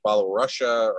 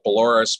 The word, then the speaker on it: Belarus: I always mispronounce Belarus. I'll sometimes say Belarusia or Belarus, but it's actually Belarus.